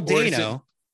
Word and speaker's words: Dano: There's an interview in like THR or Variety or something where they're talking to him, and they said it rhymes with Drano. I was like, no shit, go figure. So Paul Dano.